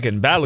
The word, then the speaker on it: Balogun: Balogun. – Like toboggan.